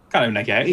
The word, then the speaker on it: Kylie Minogue: Do you know why Kylie Minogue was in the... that's how you didn't pronounce her name, right? – Kylie Minogue.